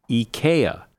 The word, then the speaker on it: IKEA: Who doesn't say IKEA? But the correct pronunciation is IKEA.